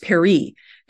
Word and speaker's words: peri: So, for example, the verb to go out, pronounced peri, is written with the house sign, followed by an alphabetic sign representing the consonant R.